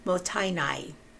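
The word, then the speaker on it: mottainai: Or you are very sophisticated and want to employ the Japanese concept of motainai. I looked up the pronunciation. It's M-O-T-T-A-I-N-A-I.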